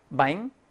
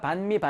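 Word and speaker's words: bánh: 바잉? 응, 빵. 왜 우리가 반미, 반미 하냐면, 남부에서는 이 바잉을 반이라고 합니다. 발음이. 조금 달라요. 북부와 남부가.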